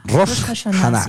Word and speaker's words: Rosh Hashanah: También hoy es el Día Mundial, hoy 11 de septiembre, del Año Nuevo Judío o del Rosh Hashanah. No sé si lo pronuncié bien, me corriges. Rosh Hashanah. O en español, Año Nuevo Judío. – Rosh Hashanah. – Sí. Eso, Rosh Hashanah.